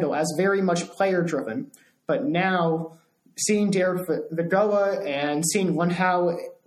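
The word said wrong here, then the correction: Luhnow: is that how you pronounce his name? – I believe it's Luno. Thank you.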